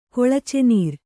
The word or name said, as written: ಕೊಳಚೆನೀರು